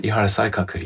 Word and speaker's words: Ihara Saikaku: As the representative figure of this period, you have chosen another writer, and this is Ihara Saikaku. Have I pronounced that right? – Yes, Ihara Saikaku, yes.